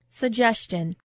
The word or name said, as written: suggestion